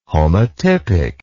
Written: homotypic

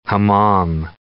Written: hammam

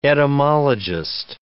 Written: etymologist

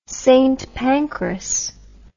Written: St Pancras

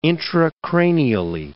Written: intracranially

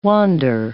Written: wander

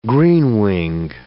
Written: greenwing

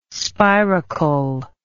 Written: spiracle

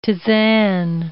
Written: tisane

